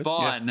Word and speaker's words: Vaughn: Did I say that right? – Mm-hmm. I think so. – Vaughn. Yeah. That's right.